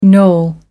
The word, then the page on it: noel: American English